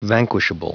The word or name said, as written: vanquishable